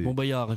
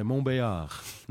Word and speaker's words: Montbéliard: Montbéliard, effectivement, on ne prononce pas ouais. – le L. – Ouais, Montbéliard.